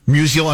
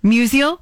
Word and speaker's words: Musial: And then uh, in November, the St. Louis Sports Commission honored Isaiah with the, how do you say this? – Musial, I think. – Musial? – Yeah. – Award. Uh, it's named after the Cardinal great Stan Musial. – I think I'm, that, I, right. hope we're saying that right.